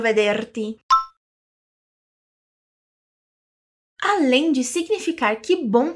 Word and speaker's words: vederti: E agora prestando atenção na pronúncia do T e do I juntos, que não fica ti, mas sim ti. Vamos lá. Vederti. Vederti. Benissimo!